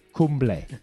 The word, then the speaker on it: Kumble: Arnel Kimball. Kimball. I can't pronounce his name properly. – Kumble. – Kumble. – Kumble. Yeah. Arnel Kumble.